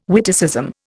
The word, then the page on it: witticism: Indian